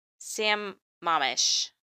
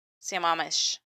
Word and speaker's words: Sammamish: It's Sammamish. No, I think I said that right. Sammamish.